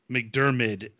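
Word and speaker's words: McDiarmid: So, um, the person who played the person, the only one person has played the emperor in the entire series, Ian McDermott. McDermid, I can't I never say his name right.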